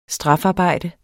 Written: strafarbejde

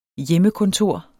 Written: hjemmekontor

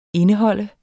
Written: indeholde

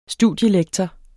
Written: studielektor

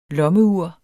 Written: lommeur

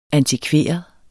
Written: antikveret

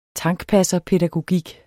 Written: tankpasserpædagogik

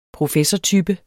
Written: professortype

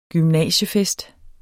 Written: gymnasiefest

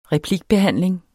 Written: replikbehandling

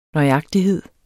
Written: nøjagtighed

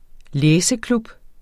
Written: læseklub

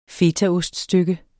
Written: fetaost

